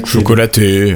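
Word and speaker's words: Chocolatey: Il y avait eu des petites tentatives de Microsoft où on avait aussi euh, Chocolati, euh, je ne sais pas comment on prononce. Euh, Chocolaté. – Euh. Chocolaté, oui, qui était un gestionnaire de paquets justement, mais euh, non officiel, même si développé en partie par des employés de Microsoft.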